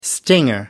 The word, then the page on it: stinger: British English